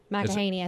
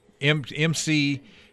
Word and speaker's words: McEnany: McEnany, I think is how it's said. We had this discussion yesterday. – McEnany. M-C, capital E-N-A-N-Y.